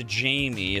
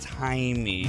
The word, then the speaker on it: Jaime: Says, I'm reaching out to request a birthday shout out for my husband, Jaime, as opposed to Jamie. It's Jaime. Mm. That is the Spanish pronunciation, and, you know, they are.